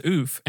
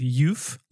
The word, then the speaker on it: Euph: Funny fact about that is that I think when I wrote that, it's spelled E-U-P-H. Yeah. And you read it as Oof. And I originally thought of it as Youf, like euphoric. Mm. And I was like, I actually like Oof better.